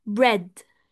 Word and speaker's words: bread: بريد اللي هو خبز بيتكتب يا شباب بي ار اي اي دي. ثانك يو تسنيم. بريد، بريد، اوكي. فهو بيتنطق شورت اي بس هو بيكتب اي اي. طيب لاحظتوا بقى الفرق هنا لما بقول لكم في فرق ما بين الحروف والاصوات؟ لانه هنا في اي اي فالمفروض النطق مختلف، بس هو وفقا لقواعد الصوتيات هي بتتنطق اه شورت اي بريد.